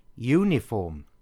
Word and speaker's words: uniform: Uniform. Now this word begins with the same U, but the pronunciation, uniform, sounds like it begins with a Y, U, so a uniform.